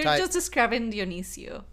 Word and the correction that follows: Dionysus: Dionysus, yes, I didn't know how to say it.